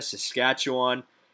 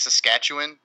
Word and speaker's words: Saskatchewan: But also, apparently it's Saskatchewan, not Saskatchewan. That's like a weird pronunciation thing. – Whatever. Canada, whatever. Saskatchewan.